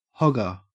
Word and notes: hogger: Australian English

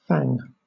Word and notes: fang: British English

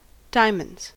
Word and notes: diamonds: American English

